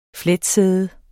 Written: fletsæde

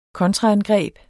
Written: kontraangreb

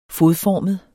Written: fodformet

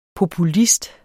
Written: populist

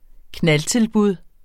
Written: knaldtilbud

